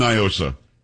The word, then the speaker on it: NIOSA: They try to pronounce it like it's Spanish. It's not. It's an acronym. And for those around the country, it's Night in Old San Antonio. And here we've always said Niosa.